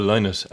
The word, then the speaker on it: Lynott: And as well, it was so lovely that it was Phil Lynott, or Phil Lynott, as I realised his family pronounce his name.